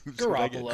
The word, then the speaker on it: Garoppolo: Garoppolo? How do you say his name? – Garoppolo.